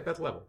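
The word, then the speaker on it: Leavel: Yeah, Beth Level, yes.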